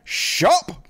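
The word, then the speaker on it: Schaap: You guys remember Pastor Jack Shop? Rhymes with cop.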